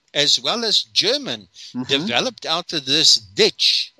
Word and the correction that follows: Diets: Dietz. – How do you spell it? – D-I-E-T-Z? – D-I-E-T-S.